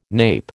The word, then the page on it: nape: American English